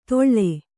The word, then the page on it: ದೊಳ್ಳೆ: doḷḷe; Kannada